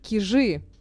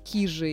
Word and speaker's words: Кижи: Кстати говоря, кантели, опять же, ударение на первый слог, да, и самая популярная ошибка, собственно, у всех людей, все говорят «кижи». А на самом деле, правильно, опять же, на первый слог, и вот правильно говорить «кижи», и мы едем на «кижи».